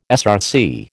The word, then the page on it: SRC: American English